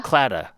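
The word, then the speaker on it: Claddagh: It, like, for example, cladog, is that how it's pronounced? – Clada, sorry, forgive me.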